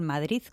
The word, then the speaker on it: Madrid: y ahora voy a decir el Madrid con Z, que es muy de aquí, ¿eh? Uh-huh. Que mira que nos cuestan los madrileños pronunciar ese Madrid bien.